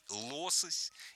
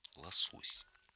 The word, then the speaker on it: лосось: И только несколько лет назад филологи наконец-то разрешили наравне с нормой «лосось» использовать норму «лосось».